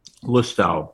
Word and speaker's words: Lustau: I love that red breast uh, Lotau. Is that how you say it? Lustau.